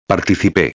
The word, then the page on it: participe: espanhol